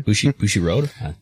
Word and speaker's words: Bushiroad: Bushiroad? – That's yeah, okay.